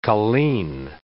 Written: colleen

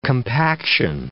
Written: compaction

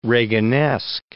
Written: Reaganesque

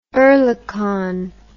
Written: Oerlikon

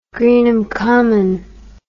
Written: Greenham Common

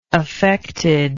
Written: affectedness

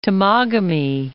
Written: Timagami, Lake

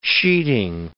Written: sheeting

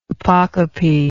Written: apocopic

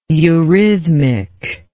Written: eurhythmically

